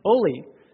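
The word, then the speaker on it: Ole: It's O-L-E. How do you pronounce that? Oli.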